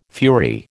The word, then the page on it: fury: American English